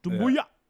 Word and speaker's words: Doumbouya: Doumbouya.